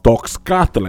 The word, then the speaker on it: Toxcatl: When I was looking into it, every time someone will enunciate it in text, it would always sound like the word was Toxcatl, because it's spelled T-O-X-C-A-T-L. There was only one YouTube video of this Mexican professor from Mexico City, and she pronounced it Toxca. So that's the enunciation I'm going to go with.